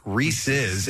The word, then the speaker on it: Reese's: So therefore, the proper pronunciation would be Reese's, as in possessive. – Right. – Reese's peanut butter cup, not Reese's.